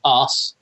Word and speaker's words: arse: So, in the UK, when you pronounce that word, the R is soft, like almost silent. – I don't know. You tell me. Arse. – Yeah. – Yeah, because to me, it sounds like you're saying ass, but you're changing the A to os.